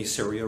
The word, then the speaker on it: Saria: So I'm going to talk about what we've been doing over the last 4 years when we launched Saria Brewing Company. Saria rhymes with area.